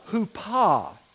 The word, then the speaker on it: Huppah: The way we would spell it in English would be K-I-D-D-U-S-H-I-N. U-S-H-I-N, Kedushin. And then the Huppah, H-U-P-P-A-H.